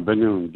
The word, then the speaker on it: Banoun: Yeah, Banoun.